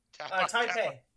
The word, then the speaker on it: Taipei: Then we get the, how do you say that? Tapai? – Taipei. – Taipei.